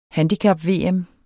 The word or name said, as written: handicap-VM